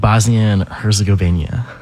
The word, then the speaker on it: Bosnia-Herzegovina: Bosnia-Herzegovina.